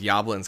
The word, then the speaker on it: Yablans: Like, I'm going to go with Yablans because it rhymes with goblins. Yeah. – Yablans.